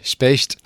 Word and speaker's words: Specht: Alors, je ne sais pas si je vais bien le prononcer, ça s'appelle Specht. – Oui, Specht. – Ah, j'ai bien vu. Okay. – Bravo. À l'allemande avec le umlaut sur le E.